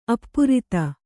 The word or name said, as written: ಅಪ್ರತಿ